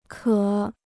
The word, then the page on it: 渴: kě